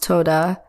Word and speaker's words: todah: And I went ahead and did some research on the Hebrew word for things, and it is Toda, pronounced, oh, excuse me, it's pronounced Toda, spelled T O D A H.